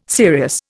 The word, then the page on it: serious: Indian